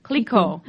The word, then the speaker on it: Clicquot: I want to talk a little bit about your time as CEO of Clicquot. Is that how you say it? – Clicquot.